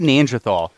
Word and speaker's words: Neanderthal: How do you say Neanderthal? Tall or Neanderthal. – Tall? – Neanderthal. – Yeah, because I had a teacher say it's tall.